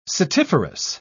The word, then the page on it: setiferous: British English